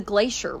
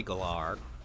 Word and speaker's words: Galar: I took a shot at the dark at the actual pronunciation of it. – I am absolutely going to go with your gut on that. That seems significantly better than anything I was coming up with. – I say Galar.